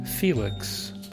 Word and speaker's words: Phoelix: From the album Room 25, that song called Window, featuring Felix, but spelled like Phoenix with an L.